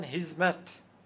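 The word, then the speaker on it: hizmet: و بعد به ترکیه استانبولی هم رفته، جالبه اونجا هم که خ ندارن، ه تلفظ میکنن، خدمت رو میگن هیزمت، هیزمتکار یعنی خدمتکار، کلمه رایجیست، خدمتکار میگن هیزمتکار.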